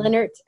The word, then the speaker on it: Linert: It's Kimberly, K-I-M-B-E-R-L-E-Y, and Linert, L-I-N-E-R-T.